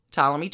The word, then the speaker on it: Ptolemy: Cleopatra's older sister, Bernice, she attempts to take her daddy, Ptolemy XII, out of the running. It's pretty bad. – Permanent silent P.